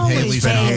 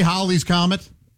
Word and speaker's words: Halley's Comet: You're going to say Halley's Comet? – No. – Yeah, he is. It's pretentious. He is. Of course he is. – I'm going to say it's a comet that rhymes with rallies.